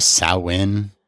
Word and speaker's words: Samhain: Yeah, it says pronounced Samhain, like Sow-in, but it still doesn't, you know, is that Sow or So? – So Samhain, Samhain.